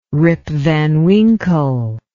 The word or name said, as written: Rip Van Winkle